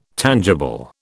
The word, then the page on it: tangible: British English